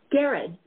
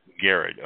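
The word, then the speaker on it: Garrod: If anybody wants to check this out, the website, Is it Gerard or Gerard? Is it hard G or soft G? – It's Gerard, like Harrods in London, but with a hard G. Gerard. – Okay, Gerard.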